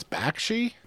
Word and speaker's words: Bakshi: Ralph Bakshi stuff, saying his name wrong. Batsky? – I think it's Bakshi, but somebody else, somebody's yelling at both of us right now.